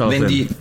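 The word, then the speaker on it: Alocén: Sagst du denn auch Alocen, also mit dem ja. – TH? – Carlos Alocen.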